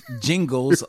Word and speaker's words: Gingles: It's Jingles, like Jingle Bells."